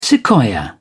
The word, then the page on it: sequoia: British English